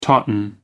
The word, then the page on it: tauten: American English